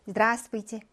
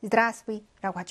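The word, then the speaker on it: 드라스브이지 (здравствуйте): 드라스브이지. 드라스브이라고 하죠. 여기에서 배 발음이 나지 않습니다.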